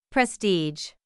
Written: prestige

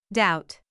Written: doubt

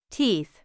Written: teeth

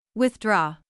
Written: withdraw

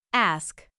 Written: ask

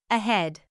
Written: ahead

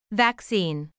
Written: vaccine

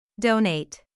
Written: donate

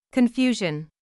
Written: confusion

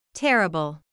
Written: terrible

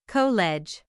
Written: college

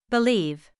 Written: believe

